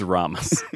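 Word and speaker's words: Ramis: It's pronounced Ramus.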